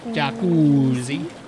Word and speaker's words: Jacuzzi: Ah, now in the jacuzzi. That's how it's pronounced here. Jacuzzi.